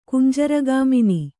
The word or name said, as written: ಕುಂಜರಗಾಮಿನಿ